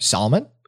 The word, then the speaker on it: Salman: Nope. Just two syllables. – All right, walk me through it one more time. – Yep. Salman. – Salman. – Salman Rushdie. – Salman. – Salman. – Salmon. – So not salmon, salmon.